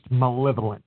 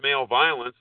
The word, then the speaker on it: malevolence: Okay, if you don't mind me correcting you just a little bit, no offense, but that's actually pronounced malevolence. – Mo- Mo- that's, you probably not never seen the word before, so. – Well, I, have seen the words male violence.